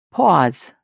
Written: Pause

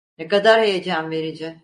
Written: heyecan

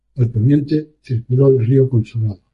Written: poniente

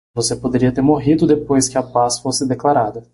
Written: declarada

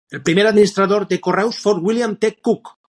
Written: correus

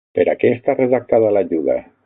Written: redactada

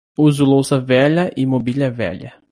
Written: louça

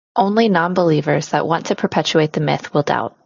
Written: believers

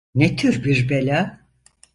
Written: bela

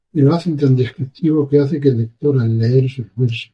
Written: descriptivo

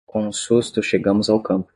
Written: campo

